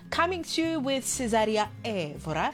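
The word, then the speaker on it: Évora: It's Cesaria Evora, he says, not Evora, like we usually say there in the townships. Cesaria Evora.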